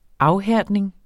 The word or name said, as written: afhærdning